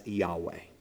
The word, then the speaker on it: Yahweh: So the actual name is YHWH, usually written and pronounced as Yahweh.